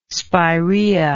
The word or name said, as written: spirea